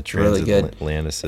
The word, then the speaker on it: Transatlanticism: Transatlanticism. – Yeah, Transatlanticism.